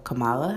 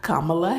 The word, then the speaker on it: Kamala: So the vice presidential debate was between Senator Kamala Harris, or Kamala Harris, gotta say her name right.